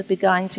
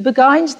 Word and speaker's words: Beguine: You can say Beguine. You know, probably that's how English people say it. I say Beguine because that's how it's said in Holland, where I worked.